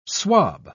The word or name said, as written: swab